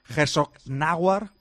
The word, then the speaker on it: Herzogenaurach: en un pueblo que se llama Herzogenaurach, así se pronuncia.